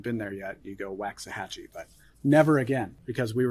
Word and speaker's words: Waxahachie: We learned from a native Texan how to pronounce what city correctly? – Waxahachie. Waxahachie. – Waxahachie. It's not even spelled the way you think it is.